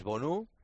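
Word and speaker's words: Bounou: y su, digamos que el nombre teóricamente, como se pronuncia, es Bonu.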